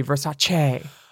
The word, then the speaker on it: Versace: And it's a hard C, Versace. Not that she even says her name right. Well, it is Versace. It's not Versace.